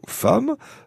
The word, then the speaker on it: femme: Alors, bien sûr, il faut rappeler l'histoire du mot femme. D'emblée, Robin, rien d'évident à prononcer femme, F-A-M-E, finalement, alors que le mot s'écrit comme j'aime, G-E-2-M-E.